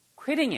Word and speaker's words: quitting: I insisted on his quitting it. Quitting. 발음이 Q 발음이요. Q, Q, quit. Qui 이렇게 발음하셔야 돼요. Killing이 아니고 Qui, Qui.